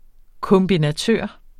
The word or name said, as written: kombinatør